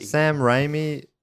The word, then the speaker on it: Raimi: Sam Raimi, am I saying that right? – Yeah.